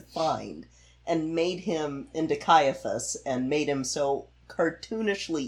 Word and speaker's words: Caiaphas: Is it Caiaphas? – Caiaphas.